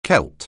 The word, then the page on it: Celt: anglais